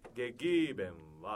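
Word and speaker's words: gegeben: ゲゲ普通に読むとゲゲーベンみたいな感じなんですけど、はい、あのアクセント E が 3 つありますよね。でアクセントはちょっと狭くなるんです。ゲゲーみたいな、ちょっとイみたいに狭くなるんです。でゲゲーベンは 2 つ目の E がアクセントです。で語尾は曖昧になります。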